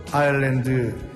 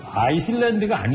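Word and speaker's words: Island: 제목은 그 아일랜드 인더스트리. – 아이슬란드가 아니죠. – 뭡니까? – 아일랜드죠. – 아일랜드니까? 왜 근데 S는 묵음이죠, 묵음.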